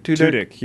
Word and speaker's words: Tudyk: Tudyk, yeah.